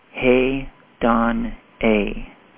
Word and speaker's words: hedone: It is hedone. It's spelled H-E-D-O-N-E, hedon-a, from which we get hedonism.